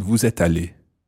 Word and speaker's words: vous êtes allé: And again, because there's an S on the end of vous êtes, you can pronounce it as you move into the vous êtes allé, or you can make it silent.